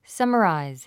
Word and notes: summarize: sʌ́məràiz; サマライズ

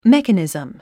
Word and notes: mechanism: mékənìzm; メカニズム

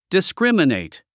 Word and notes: discriminate: diskrímənèit; ディスクリマネィト

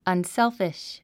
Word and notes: unselfish: ʌnsélfiʃ; アンセルフィッシュ

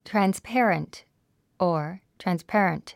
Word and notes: transparent: trænspéərənt; トランスペアレント

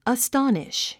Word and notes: astonish: əstɑ’niʃ; アストォニッシュ

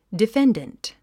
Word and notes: defendant: diféndənt; ディフェンデント

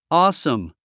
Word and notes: awesome: ɔ́ːsəm; オーサム

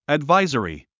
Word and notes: advisory: ædváizəri; アドバイザリィ

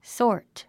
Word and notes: sort: sɔ’ː r t; ソート

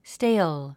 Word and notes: stale: stéil; ステイゥ

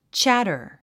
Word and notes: chatter: tʃǽtə r; チャタァ

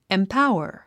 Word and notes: empower: impáuər; インパウワァ

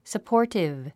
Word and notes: supportive: səpɔ́ː r tiv; サポーティブ

